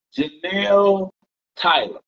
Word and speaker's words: Jenelle Tyler: Jenelle Tyler.